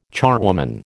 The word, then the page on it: charwoman: American English